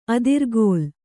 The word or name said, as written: ಅದ್ರಿಕೀಲೆ